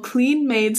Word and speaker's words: Kleen Maid: They began marketing, it's called Clean Made Sliced Bread, but it's spelled K-L-E-E-N-M-A-I-D.